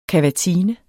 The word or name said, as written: kavatine